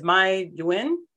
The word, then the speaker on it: MyDuyen: And that is MyDuyen.